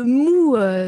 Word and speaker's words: mu: Vous évoquez la notion, je ne sais pas si c'est la notion ou plutôt un concept, le mu ou le mou, je ne sais pas comment ça se dit. – Le mou. – Voilà, M-U, qu'on dit mou. – On se prononce mou en japonais, voilà.